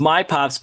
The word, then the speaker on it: MiPops: It's My Pops, but it's spelled M-I-P-O-P-S. So for those that want to Google it or look on Facebook or Instagram. – M-I for Michigan.